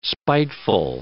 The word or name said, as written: spiteful